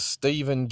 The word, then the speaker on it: Stephen: My name is Stephen Gilbreth, and I spell it S-T-E-P-H-E-N.